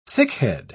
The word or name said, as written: thickhead